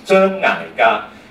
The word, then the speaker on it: 张艾嘉: 那他签约给嘉禾公司的时候呢，他原名叫张艾嘉，但是公司就觉得说一个艺人的名字很重要，因为他代表了一种意头嘛，所以如果张艾嘉用广东话香港哦，他念成就是真矮嘎，矮听上去呢，哦糟糕，有点像矮呀，就是癌世界的癌，就是要熬的意思，就很惨，所以他们就建议给他说，要不要把你的名字改成，发音很相似。